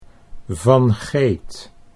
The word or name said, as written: Van Geet